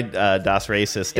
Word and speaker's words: Das Racist: This song is by Das Racist. – Das Racist. – I always say it wrong.